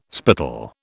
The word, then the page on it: spittle: British English